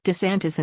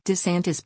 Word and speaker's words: DeSantis: pronunciation, DeSantis insisted his last name should be pronounced as winner.